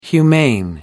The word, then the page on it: humane: British English